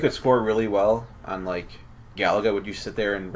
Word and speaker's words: Galaga: It is pronounced Galaga.